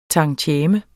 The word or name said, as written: tantieme